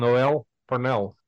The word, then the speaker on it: Noel Parnell: And today it is my pleasure and a privilege to welcome Noel Parnell. Hi, Noel. Am I pronouncing your name hey. correctly? You are, you are.